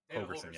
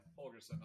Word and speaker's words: Holgorsen: It's Holgorsen. Yeah. – Holgorsen.